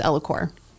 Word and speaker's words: Ellacor: The technology is microcoring, and our product name is Elicor. – That's a lot. So Elicor, Elicor. How do you spell that? – E-L-L-A-C-O-R.